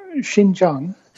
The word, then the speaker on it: Xinjiang: How do you say that? Is it Xinjiang? – Xinjiang.